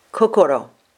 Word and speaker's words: kokoro: But this character alone is pronounced kokoro. Kokoro would have been the original Japanese pronunciation.